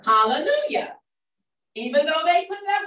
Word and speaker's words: Hallelujah: Hallelujah. – Hallelujah. Whether it's written in that book with the J or the I, it's still pronounced hallelujah.